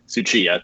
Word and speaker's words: Tsuchiya: It's Kyle, K-Y-L-E, Tsuchiya, T-S-U-C-H-I-Y-A.